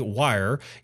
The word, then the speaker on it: Wyre: On November the 5th, 2019, Bitcoin-focused product hub Coin Ninja announced that through a collaboration with cryptocurrency payments company Wire, users can now buy Bitcoin directly on the Dropbit app with Apple Pay and Google Pay. Wire here is spelled W-Y-R-E.